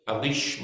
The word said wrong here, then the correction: Eilish: McColgan. Is it Eilish? Eilish. There's always somebody, I'll tell you the right name. I never get the right names. Eilish McColgan.